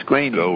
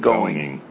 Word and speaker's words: Groening: It's Matt Groening. It's Groening, not going.